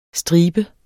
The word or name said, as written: stribe